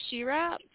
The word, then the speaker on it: Shira: My name is Shira from Family First Care LLC.